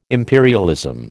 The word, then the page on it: imperialism: American English